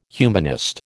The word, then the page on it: humanist: American English